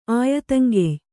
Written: ಆಯತಂಗೆಯ್

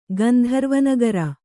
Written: ಗಂಧರ್ವನಗರ

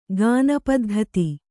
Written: ಗಾನಪದ್ಧತಿ

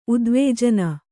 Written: ಉದ್ವೇಜನ

